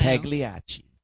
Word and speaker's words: Pagliacci: Pagliacci. – You know, yeah. Explain that to him. I can't even say the word. – The opera Pagliacci.